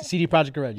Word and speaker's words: CD Projekt Red: CD Projekt Red.